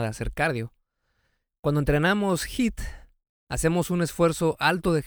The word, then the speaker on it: HIIT: ¿A qué nos referimos cuando decimos hit? Hit eh, se escribe H I T de Tito y significa High Intensity Interval Training,